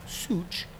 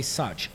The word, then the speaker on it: Szucs: S-Z-U-C-S. Technically pronounced such. We say such. Like, it's such a pleasure such.